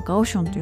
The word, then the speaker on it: 高雄: 中国語ではガオションというふうに言うんですけれども、その、まあ、いわばその福岡と鹿児島ぐらいの距離しかないんですけれども、気温が全然違います。